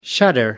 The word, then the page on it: shudder: American English